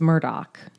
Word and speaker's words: Murdaugh: Now, the name looks like it's spelled Murdaw. – Yeah. – But as Mandy explained in her podcast, everyone knows it's Murdoch in South Carolina.